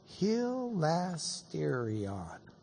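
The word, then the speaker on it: Hilasterion (ἱλαστήριον): Hilasterion.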